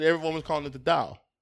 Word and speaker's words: Tao: It's T-A-O, but it's pronounced Tao.